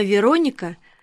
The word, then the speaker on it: Вероника: Имя Вероника произносится с ударением на «о», — поправил меня Славик. «От города Верона».